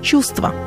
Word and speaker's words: чувство: Например, в труднопроизносимых сочетаниях звуков один из них может выпасть, как в слове «чувство», в котором утрачивается звук «в», и мы говорим «чувство».